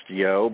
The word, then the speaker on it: Yeo: And his associate's name is, it's pronounced Yo, but it's actually spelled Y-E-O.